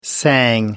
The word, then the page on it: sang: American English